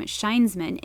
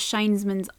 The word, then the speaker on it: Shinesman: For example, the name Shinesman is actually a pun. The pronunciation of shine is very close to the Japanese word shine, which is a word used to describe a company employee.